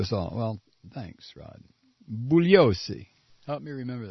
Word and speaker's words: Bugliosi: I believe the G is silent, as it is in lasagna. If you address him as bugliosi, I'll bet he'll appreciate you more.